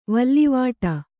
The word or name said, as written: ವಲ್ಲಿವಾಟ